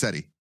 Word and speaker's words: Sethi: I think it's Sethi. – I think it is too. – Yeah, I think it's Sethi. – The H is silent. – Yep. It's like a Egyptian name. Sethi.